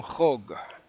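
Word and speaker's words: hog (חוק): Primero, antes de nada, quiero decir, hermanos, que el día de las trompetas es un hog. Un hog. ¿Okay? Eso se escribe J-O-K. Hog.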